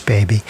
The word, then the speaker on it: BBBY: Also heard from Dave Matthews, Jack Johnson, Southern Avenue, and Baby, but we pronounce that Baby here. Yeah, they spell it just B-B-B-Y, but we know it's Baby.